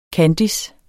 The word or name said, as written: KANDISSEN